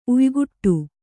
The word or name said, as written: ಉಯಿಕುಟ್ಟು